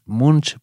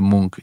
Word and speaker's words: Munch: y, me acuerdo que que dijo el guía, no, esto es, era una visita así, y me decían, esto es Munch, pero se dice Munk.